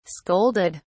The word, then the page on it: scolded: British English